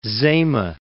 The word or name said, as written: Zama